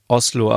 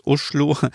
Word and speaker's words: Oslo: wie Oslo, aber eigentlich heißt es Uschlo.